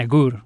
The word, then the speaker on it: agur: And agur, which is goodbye.